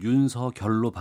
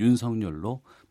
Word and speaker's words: 윤석열: 이 이름 발음 때문에 많은 분들이 얘기를 하시던데 표기는 윤석열이고요. 어문규정 표준 발음법에 따르면은 윤서결로 발음하는 게 맞는데 네. 본인이 그냥 윤석열로 발음해달라고 해서 시사본부에서는 윤석열로 발음하도록 네.